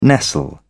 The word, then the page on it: nestle: British English